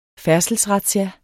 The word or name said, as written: færdselsrazzia